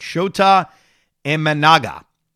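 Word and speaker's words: Shota Imanaga: Now, one of the other now what's is Shoto Imenga. Let me pronounce his name right. Shota Imenaga, who is another Japanese pitcher.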